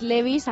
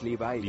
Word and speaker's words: Levi's: Sí, hoy te estamos contando además que los vaqueros Levi's acaban de cumplir 140 años, ya, 140 años. – Levi, ¿no? Los Levi's.